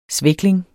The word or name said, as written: svækling